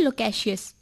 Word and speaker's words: loquacious: और आज का इंग्लिश वर्ड है लोकेशियस, जिसकी स्पेलिंग है LOQUACIOUS, और इसका अर्थ है बहुत अधिक बोलने वाला या वाचाल।